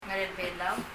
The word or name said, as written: Ngerél a Belau